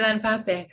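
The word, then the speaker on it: Ilan Pappé: Ellen Pape? Is that how I pronounce his name? – Oh, yeah, Ellen Pape.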